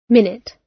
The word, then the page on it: minute: British English